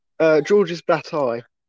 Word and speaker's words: Georges Bataille: Say his name one more time. – George's Bataille. – Georges Bataille. – Yeah.